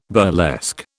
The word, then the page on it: burlesque: British English